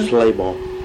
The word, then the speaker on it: Slabaugh: Levi, and the last name is pronounced, sir? – Slabaugh. – And that's spelled S-L-A-B-A-U-G-H on seat, Martin Overholt, and Jerome Overholt.